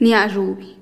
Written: niazhubih

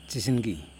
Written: tiziNgi

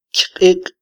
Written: kyqiq

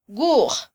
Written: wuj

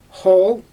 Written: xool

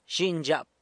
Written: zhinyzhyap

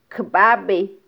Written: kbabe

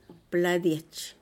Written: pladiech

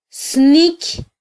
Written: sniky

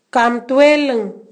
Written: Kamtueling

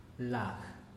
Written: lag

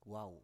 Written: wau

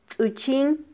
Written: tzuchin

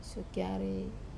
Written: sokiare

